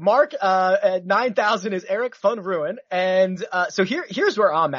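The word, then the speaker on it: Van Rooyen: And Eric Van Ruin, I believe is how Mark Immelman taught me how to pronounce that. – Yeah. Nobody else pronounces it that way, by the way.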